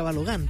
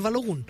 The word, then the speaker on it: Balogun: muy mal le decía Balogun, porque yo creía que así se pronunciaba, digamos, es norteamericano, entonces termina en U, debe ser Balogun. No, es Balogun.